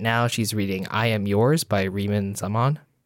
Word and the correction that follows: Rima: I think it's pronounced Rima. Rima Zaman.